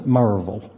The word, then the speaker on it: Maryville: That's spelled Maryville, okay, but they pronounce it Merrillville, all right?